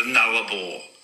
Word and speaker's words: Nullarbor: Mate, just of interest, it's pronounced the Nullarbor. So the Nullarbor. There we go, just to help.